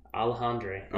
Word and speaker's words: Alejandre: Alejandre.